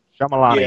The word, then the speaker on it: Shyamalan: Sh- Shyamalan, am I saying it right? – Shyamalan. Yeah, yeah. That's about yes. as close as anyone.